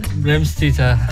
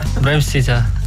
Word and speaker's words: Vremščica: Can you pronounce it? – Vremščica. Vremščica. Yeah. – Okay, perfect.